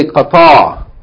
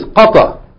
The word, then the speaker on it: Qatar: Like they say Qatar. There's no place in the world called Qatar. The name of the country is Qatar.